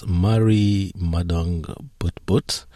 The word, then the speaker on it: Marri Madung Butbut: Now, Sydney's World Pride is just uh, around the corner, and uh, this year it will host a First Nations gathering space, Marri Madung Butbut. Can you tell us more about uh, this and uh, what is this gathering space? – Sure, very good pronunciation, by the way.